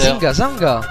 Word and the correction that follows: Zinga Zanga: Zingo Zango, c'est comme ça qu'on prononce. – Mais pourtant je vois des A. – Oui, mais c'est à l'occitane et les A finaux se prononcent.